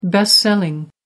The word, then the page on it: best selling: American English